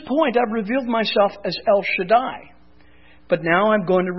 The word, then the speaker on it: El Shaddai: We said anytime you see the English translation God Almighty in your Bible, it is most times the Hebrew word El Shaddai. And there are the Hebrew letters so that you can all see. That would be pronounced El Shaddai.